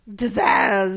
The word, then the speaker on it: Zsasz: It's Zaz. – It's capital Z S A S Z. – I know, and I can't. – Zaz.